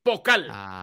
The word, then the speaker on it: Pokal: ¿Cómo se escribe? ¿Vocal? – P-O-K-A-L. Pocal.